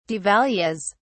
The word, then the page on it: devalues: British English